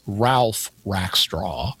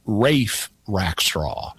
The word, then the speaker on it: Ralph Rackstraw: You'd say it as an American. His name is Ralph Rackstraw. But if you watch performances, they use British pronunciation and often call him Rafe Rackstraw. Uh-huh. And I actually knew a Rafe growing up, but his name was spelled without the L.